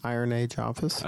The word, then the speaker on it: Iron Age Office: You say Iron Age Office. – Iron Age Office. – Okay, that's better. – Is it? – When I say it, people are like I-O-N Office.